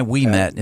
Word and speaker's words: Ouimet: It is O-U-I-M-E-T. O-U-I-M-E-T. And you can... – O-U-I-M-E-T. – Yeah, Levi Wemet is how it's pronounced.